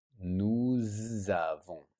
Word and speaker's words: nous avons: Okay, so if it's only nous, it's a silent S. But if the next word starts with a vowel, nous avons, and this is silent, nous avons, the S is silent, nous avons, vous avez, et, not es, not vous avez, no, vous avez, et, vous avez.